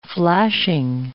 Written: flashing

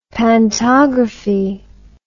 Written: pantography

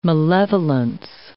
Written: malevolence

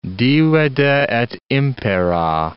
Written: divide et impera

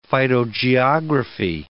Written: phytogeography

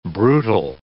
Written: brutal

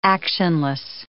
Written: actionless

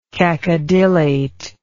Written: cacodylate

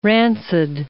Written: rancid

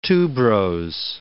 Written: tuberose

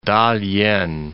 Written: Ta-lien

